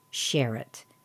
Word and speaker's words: Sherratt: So, let's take a deeper look into the name Sherritt. It's spelled S-H-E-R-R-A-T-T.